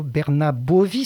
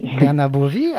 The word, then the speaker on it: Bernabovi: Alors bonjour Stefano Bernabovi, c'est comme ça qu'on prononce votre nom ? – Tout à fait, Bernabovi. – Bernabovi, alors vous avez des consonances, dirais, de l'autre côté des Alpes, hein, on pourrait dire ça.